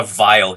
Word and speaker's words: Weyl: And I say his name is spelled W-E-Y-L. So he's not a vile human being. It's just, you know, but you pronounce his name vile.